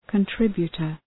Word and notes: contributor: kən’trıbjətər

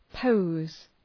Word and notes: pose: pəʋz

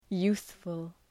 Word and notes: youthful: ‘ju:ɵfəl

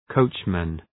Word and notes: coachman: 'kəʋtʃmən

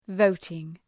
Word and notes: voting: ‘vəʋtıŋ

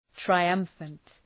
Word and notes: triumphant: traı’ʌmfənt

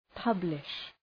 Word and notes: publish: {'pʌblıʃ}

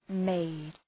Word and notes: maid: meıd

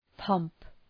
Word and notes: pomp: pɒmp

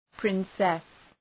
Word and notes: princess: ‘prınsıs

